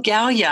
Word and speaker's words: Galia: So Galia, I mean, I know that's not what we're going to talk about, but did I pronounce that right? – You did pronounce that right.